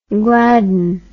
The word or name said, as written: gladdon